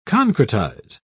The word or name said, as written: concretize